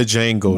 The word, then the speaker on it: Django: Yeah, that, and it's not the Django, nigga. The D is silent.